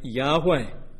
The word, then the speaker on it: Yahweh: And as close as we can get to it, the pronunciation was probably something like Yahweh. Yahweh.